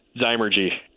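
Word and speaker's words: Zymurgy: How do you say that? – Zymergy. The science of fermentation. – Oh, okay. And it begins with an X or a Z? – It begins with a Z.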